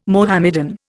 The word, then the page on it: Mohammedan: Indian